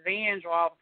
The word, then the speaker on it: Vandroff: I wanna play on the safe side because I don't want to butcher your name because people, my last name is Vandroff. People like Vandroff, Vanderhoff. So listen, I'm right.